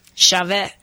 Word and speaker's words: Shaveh: Shaveh.